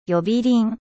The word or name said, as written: 呼び鈴